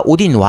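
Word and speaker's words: Odin: 특히 게임을 플레이해 보면 첫 번째 주인공이 이제 그웬돌린이라는 여자의 그 발키리 여자인데 얘 아버지가 오딘 왕이에요. 그러니까 게임 내에서는 오다인이라고 나오는데 그냥 그게 오딘이니까. 네, 오딘이니까. 그러니까 아이를 아이로 발음하냐 이로 발음하냐 차이밖에 없으니까.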